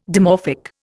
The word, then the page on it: dimorphic: Indian